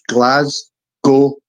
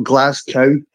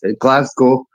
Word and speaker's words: Glasgow: So for people that don't know, in Scotland, and in particularly Glasgow, and it's pronounced Glasgow, not Glasgow. For all you Americans out there, it's not some fucking like see-through farm animal. It's Glasgow.